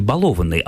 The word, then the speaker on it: балованный: А это тоже создает проблему, поэтому глаголу «баловаться» просто не повезло, потому что в разных частях речи с этим корнем ударение бывает и на первом, как в «баловень», и на втором, как «балованный», и на третьем, как «баловать» в слогах.